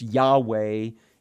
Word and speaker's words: Yahweh: It's Yahweh, as best we can pronounce it, but Lord is often the way it appears in our English Bibles, but you'll hear me say, He who is.